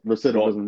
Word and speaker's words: recidivism: That's how you say it? Recidivism? – Recidivism rate. Mm-hmm.